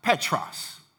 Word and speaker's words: Petros: But in the original language, when you break this down, Peter is pronounced Petros, which means small rock.